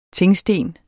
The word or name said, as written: tingsten